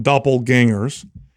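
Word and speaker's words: doppelgänger: When somebody doppelganger, did I say it right? – Ganger. Yeah. – Ganger or danger? – Ganger. – Doppelganger. So put the gang in there.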